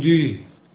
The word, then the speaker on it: 뉘: 뉘. – 다시 한번 발음. – 뉘. – 뉘. 아, 요새는 뉘에인데 옛날에는 뉘.